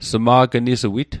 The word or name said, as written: sma'gnisuit